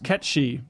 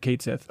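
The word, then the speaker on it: Cait Sith: Because he remembers the whole thing about, he's Scottish Gaelic, and it's pronounced Ketchy. – Yeah. – Don't be naive. What are you, Neanderthals doing, right? – Yep. – Let the record show. – Yeah. Well, Square Enix tweeted out this week, and they said, hey, by the way, we're pronouncing it Kate Sith.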